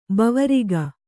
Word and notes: ಭವರೋಗ: bhava rōga

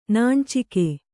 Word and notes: ನಾಣ್ಚಿಕೆ: nāṇcike

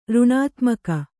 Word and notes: ಋಣಾತ್ಮಕ: řṇātmaka

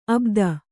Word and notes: ಅಬುದಿ: abudi

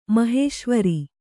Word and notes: ಮಹೇಶ್ವರಿ: mahēśvari

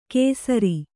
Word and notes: ಕೇಸರಿ: kēsari

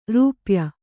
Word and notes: ರೂಪಾಯಿ: rūpāyi

